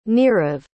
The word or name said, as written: nirav